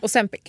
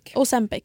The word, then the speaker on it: Ozempic: Ja, det har ju varit nu under men senaste 2 månader en stor debatt kring Ozempic. Är det så man uttalar det? – Ozempic. – Ozempic, japp, precis.